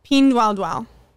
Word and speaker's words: Pinduoduo: Meanwhile, they also have another company called Pinduoduo.